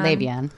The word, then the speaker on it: Le'Veon: Le'Veon? – Le'Veon.